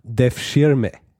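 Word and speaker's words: Devşirme: weiß ich, dass es so ausgesprochen wird, und zwar Def Schirme, die Knabenlese.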